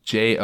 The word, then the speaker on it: Jonette: Jonette. – Okay. – Like Jeanette, but with starting with Joe.